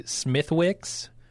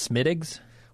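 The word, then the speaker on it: Smithwick's: Like I said, there's a brand of, I think it's beer that we would say Smithwicks, but it's actually pronounced Smittigs.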